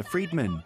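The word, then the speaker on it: Friedmann: In Beijing, students got creative by raising signs showing an equation created by physicist Alexander Friedman, whose surname is pronounced similarly to free man.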